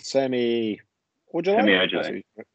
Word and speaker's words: Semi Ojeleye: We got Rodney Hood and Semi Ojeleye.